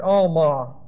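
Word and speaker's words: alma (עלמה): This is the word alma. Looks like this in Hebrew. This ayin is silent.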